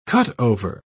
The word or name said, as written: cutover